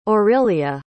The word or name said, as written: aurilia